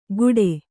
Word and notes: ಕೂಟೆ: Kannada

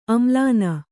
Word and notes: ಆಮೇಲಿನ: āmēlina

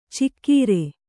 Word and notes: ಚಿಕ್ಕೀರೆ: cikkīre

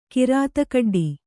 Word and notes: ಕಿರಾತಕಡ್ಡಿ: Kannada

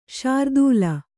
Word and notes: ಶ್ರೀತಾಲ: śrī tāla

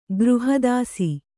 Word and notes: ಗೃಹದಾಸಿ: kannada

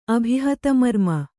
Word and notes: ಅಭಿಹತಮರ್ಮ: abhihatamarma

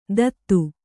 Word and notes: ತತ್ತು: tattu

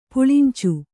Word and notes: ಪುಳಿಂಚು: puḷincu

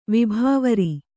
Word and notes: ವಿಭಾವರಿ: vibhāvari

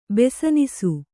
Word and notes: ಬೆಸನಿಸು: besanisu

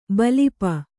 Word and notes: ಬಲಿಪ: balipa

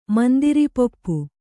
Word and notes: ಮುಂದಿರಿಪಪ್ಪು: mundiri pappu

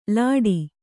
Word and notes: ಲಾಠಿ: Kannada

